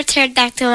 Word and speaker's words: pterodactyl: Why can't you hear a pterodactyl when it goes potty? – Why can't you hear when a pterodactyl goes potty? – Yep. – Because the P is silent.